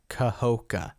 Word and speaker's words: Kahoka: I always, that syllable, it's Kahoka. It's three syllables. And I always like, I say it in one big breath.